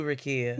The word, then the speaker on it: Rukaiyah: I follow you, Rakia.